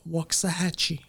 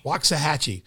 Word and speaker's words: Waxahachie: Waxahachie? – Waxahachie. – Waxahachie.